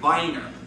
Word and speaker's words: Weiner: it should be pronounced like Weiner, is the right way to pronounce that, okay, but he probably just goes around pronouncing it like a pervert, just, it's probably some psychological thing why he even pronounces his name that way, because that is not the way that that name is pronounced, okay, E-I makes I like Einstein, okay, and that's how it's supposed to be pronounced.